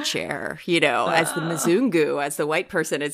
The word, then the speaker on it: Mzungu: Mzungu, yeah. – Mzungu.